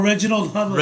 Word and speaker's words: Reginald Hudlin: Him alongside Ranald Hudlin. I'm saying his name correct. – Reginald? I don't even know. – They're longtime... – Oh, Reginald Hudlin. – Reginald, there you go. Oops, I said it wrong.